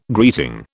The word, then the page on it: greeting: British English